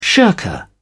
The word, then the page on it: shirker: British English